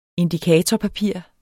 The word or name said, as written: indikatorpapir